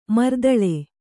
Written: ಮರ್ದಲ

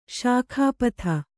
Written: ಶಾಖಾಪಥ

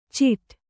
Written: ಚಿಟ

